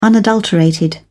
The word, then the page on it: unadulterated: British English